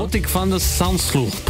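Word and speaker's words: Botic van de Zandschulp: Eieiei, auch gut ausgesprochen. Britta Kern hat es gestern auch schon richtig gut ausgesprochen bekommen. – Dann ja, versuch du auch nochmal. – Botik van de Sandschulp. – Ich hatte den schon mal im Davis Cup.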